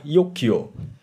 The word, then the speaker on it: Jogja: Jogja itu pun kan sebetulnya pengucapannya adalah Yogyo pakai Y tapi karena tulisannya J masih ejaan dulu akhirnya kita latah sampai sekarang mengatakan Jogja itu untuk sekelumit kisah dari asal mula terbentuknya dusun Gianti.